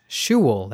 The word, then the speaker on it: Shewell: Shewell, that's spelled S-H-E-W-E-L-L.